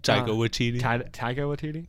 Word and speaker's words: Taika Waititi: Like because to a certain extent, like in this, at this angle, the angle Taika Waititi takes, whatever, I don't know how to pronounce his name. – Taika Waititi.